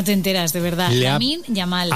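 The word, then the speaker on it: Lamine Yamal: ¿Qué pone? La mil llamal. Lamin Yamal. ¿Cómo se dice? – Lamin Yamal. – Yamal. – Bueno, pues... – Ah, es una L y una Y.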